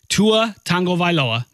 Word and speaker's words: Tagovailoa: If you watched the national championship game on Monday, of course you saw Tua Tagovailoa, that's how you say it, Alabama freshman, Tua Tagovailoa, lead the Crimson Tide to a dramatic come-from-behind overtime win.